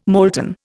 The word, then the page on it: molten: Indian